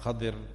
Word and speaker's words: Khidr: On the other hand, you have Khadir or Khidr alayhi salam, which is pronounced in both ways, which means green, mentioned wherever he used to lie down, the grass around there would turn green.